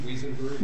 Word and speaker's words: Wiesenberg: Is that the right pronunciation of your name, Wesenberg or Wiesenberg? – Long E, Wiesenberg. – Wiesenberg, okay. – Good afternoon. Eric Wiesenberg on behalf of Prinko Corporation, Prinko America, petitioner and defendant.